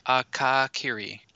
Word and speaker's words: Akakiri: But we're going to do the final episode today, which I think is pronounced Akiri-e? – Akakiri. – Akakiri. Okay. – No, and it's not... Kaka sounds appropriate, but Akakiri.